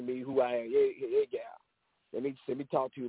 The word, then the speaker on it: gyal: the gal, spelled G-Y-A-L, and that's how we pronounce it.